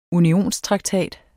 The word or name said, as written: unionstraktat